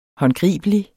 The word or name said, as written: håndgribelig